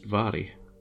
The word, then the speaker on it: Mhairi: M-H-A-I-R-I, but it's, in Scots, it's pronounced Vary, because why wouldn't it be?